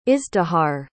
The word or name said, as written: izdihar